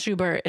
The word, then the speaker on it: Schubert: It is pronounced Schubert. It's not Schubert. It's not Schuber. It's not like anything weird. It's literally Schubert.